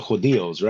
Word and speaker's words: Judíos: reaching the Jews or the Judíos, right? – Yes. That's how you say it in Spanish, right?